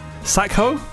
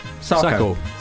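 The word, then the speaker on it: Sakho: Sackho? – Yes. – Sackho makes it sound a bit offensive, doesn't it? – Sackho! – Sacky. How would you pronounce it? – Sacko. – Sacko. Okay. Sacko.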